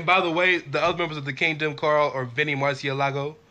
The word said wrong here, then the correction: Marseglia: I know I'm not saying his name right, but nobody does. – Marseglia. Vinny Marseglia and TK O'Ryan.